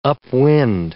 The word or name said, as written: upwind